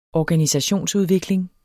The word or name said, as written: organisationsudvikling